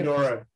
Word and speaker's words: Pomodoro: How do you say Pomodoro? – Yeah.